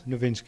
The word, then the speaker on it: Nowitzki: You know Dirk Nowitzki, do you know the basketball player? Is it Nowitzki, is it? – Nowitzki, yeah.